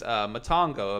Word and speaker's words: Matango: Today we are discussing 1963's Matango. Am I pronouncing that correct? – Yes.